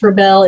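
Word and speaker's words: Rebelle: But the way I learned to pronounce Rebel is, we all know that rebel is a person who defies convention, and a belle is a beautiful woman. So a rebel is a beautiful woman who defies convention.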